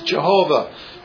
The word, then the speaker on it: Jehovah: And every time the word Lord is seen in this passage, it's the tetragrammaton, the four letters that stand for, that we pronounce Jehovah, or the Hebrews pronounce Yahweh.